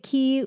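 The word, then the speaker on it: Quay: I feel like Vic Key did the celebrity influencer collaborations before anyone else did. – Yeah. – They really hit it out of the park. Did I say that correctly? – Yeah.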